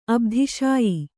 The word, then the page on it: ಅಬ್ಧಿಶಾಯಿ: Kannada